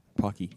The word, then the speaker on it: Pocky: Pocky. Yeah, that's how you pronounce it.